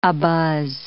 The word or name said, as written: abuzz